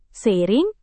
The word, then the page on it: seiring: Indonesian